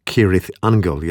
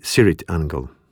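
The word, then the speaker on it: Cirith Ungol: Poprawna wymowa nazwy to Kirith Angle, jednak członkowie zespołu wymawiają ją z miękkim C na początku, czyli Sirith Angle.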